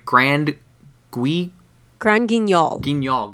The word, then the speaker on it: Grand Guignol: Grand Guignol. – Grand Guignol. That look is kind of part of that tradition. That's sort of like when you look at like some of the older like Grand Guignol horror films, like the Hammer horror films that kind of traipsed in that look.